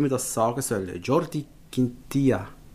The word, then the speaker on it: Quintilla: Wer wohl meiner Meinung nach eine dicke Saison wird machen, ist der, und jetzt kommt Jordi Quintilla. – Quintilla. Ist das richtig ausgesprochen? – Ja, ich habe eine Spruchnachricht bekommen von einem anderen Podcast aus Basel.